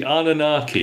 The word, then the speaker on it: Anunnaki: The Anunnaki.